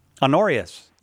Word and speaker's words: Honorius: St. Honorius, I think is how you say it, Honorius. – Honorius, yes.